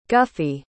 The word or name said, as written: guffy